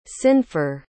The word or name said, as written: cynfor